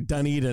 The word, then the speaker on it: Dunedin: Someone yelled at me on my Twitter about mispronouncing Dunedin, and I was like, dog, that ain't me. I think I know who it was, even though you nailed it right there, of course.